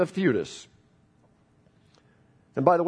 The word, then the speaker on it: Theudas: Some time ago, Theudas, also correctly pronounced Theudas, Theudas appeared claiming to be somebody, and about 400 men rallied to him.